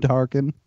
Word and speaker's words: Tarkin: Before you say who he's facing, I think you're pronouncing it wrong. It's Grand Moff fucking Tarkin.